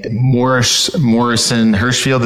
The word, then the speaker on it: Morrison Hirschfield: And for the audience listening, Morrison Hirschfield, is that how you say it? – Yep. That's right. – Morrison Hirschfield.